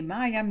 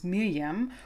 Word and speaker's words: Maryam: Zumindest wurde sie so bei den Memorials von der Stanford University ausgesprochen. Die westliche Welt in den Reportagen und News spricht sie allerdings Miriam oder Miriam Mirzakhani aus. Ich entscheide mich jetzt für iranische Aussprache und hoffe, das ist in Ordnung.